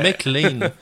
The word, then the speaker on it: McLean: Ouais, ça, les McCain, ouais, c'est ça, mais c'est, parce que c'est McLean. je l'ai mal prononcé, c'est McLean, c'est ça, qui est sous le titre des personnages de GLaDOS des jeux Portal 2.